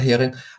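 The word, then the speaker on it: Häring: Es gibt ein Herr, da muss ich nachgucken, dass ich den Mann jetzt richtig ausspreche, ein Herr Haring oder Hering. Der ist ein wirklich... – Norbert Hering, ja. – Ja, Norbert Hering.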